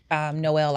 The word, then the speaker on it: Noel: Noel like Christmas, Noel.